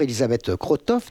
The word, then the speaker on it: Elisabeth Krotoff: Bonjour Elisabeth Krotoff, d'abord j'espère que c'est bien comme ça qu'on prononce votre nom. – Tout à fait.